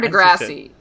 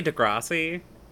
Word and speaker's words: Degrassi: Is it Degrassi or Degrassi? How do you say it? – I would say Degrassi. – Yeah. – Maybe because I'm like, they're Canadian and they like the long A sound.